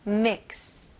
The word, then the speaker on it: mix: 네, 이 발음은 이름절로 하셔야 돼요. mix가 아니고요. mix. 마지막 X 소리로 끝내셔야 됩니다. 모음 소리가 있으면 안 돼요. 끝부분에 mix. mix.